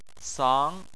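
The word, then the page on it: sang: tone 2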